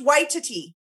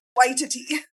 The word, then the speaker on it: Waititi: It's pronounced Waititi. Okay. Yep, I know. See, say it wrong, and now your brain doesn't like it.